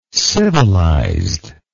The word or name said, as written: civilizedness